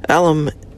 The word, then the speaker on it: Alum: Alum is not pronounced like you were referring to your alma mater. The Al is pronounced like the common nickname. Imagine your dad's brother is named Albert, and your mom grits her teeth whenever anyone mentions Al. That kind of Al.